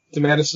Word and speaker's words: DeMatteis: Did am I saying that right? DeMatteis is one pronunciation. – Uh, it is.